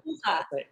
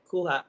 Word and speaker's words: Kuha: So, but we spelled it in a way that, you know, Americans could pronounce it the right way, the way how we say it. – Yes. – So, Kuha. Kuha, yeah.